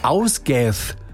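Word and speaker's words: Ásgeir: Wäre der Ausgath, so spricht man ihn aus, ein isländischer Sänger.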